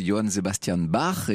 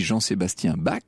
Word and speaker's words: Johann Sebastian Bach: c'est Johann Sebastian Bach, et on dit Jean-Sébastien Bach.